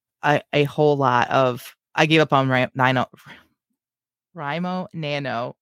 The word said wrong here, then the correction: NaNoWriMo: right? Is that right? Doesn't even sound right in my head anymore. – NaNoWriMo. – That's right, because I said it wrong.